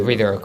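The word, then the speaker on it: Ridder: Desmond Reader o Ryder. Reader, como se pronuncia, ya no va a jugar.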